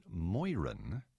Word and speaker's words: Moeran: Moran, M-O-E-R-A-N. His father was Irish, his mother English. The Irish apparently pronounce it Moiran, but I've also heard Ernest J., he also went by E.J., E.J. Marin. Be it as it may, I'll say Ernest John Moran.